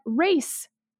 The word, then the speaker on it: res: the correct pronunciation is more like race.